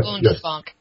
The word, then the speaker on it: Bundesbank: Is that how you pronounce it? Bundesbank? – Bundesbank, yes.